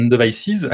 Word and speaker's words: Devices: parce qu'il faut prononcer le S à la fin, donc Platform and Devices,